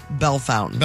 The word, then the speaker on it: Bellefontaine: B-E-L-L-E. – B-E-L-L-E. – F-O-N-T. – Oh. – A-I-N-E. – Well, there's how you probably should pronounce it, and then there's how St. Louis pronounces it. – St. Louis pronounces it Bellefontaine. – Bellefontaine, mm-hmm.